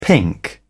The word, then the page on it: pink: British English